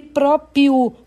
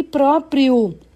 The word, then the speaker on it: próprio: Ou falam frustração e próprio, faltando R, quando o correto é frustração e próprio.